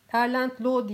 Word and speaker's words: Erland Loe: Erland Loe'nin Erland Lo diye okunuyor sanırım.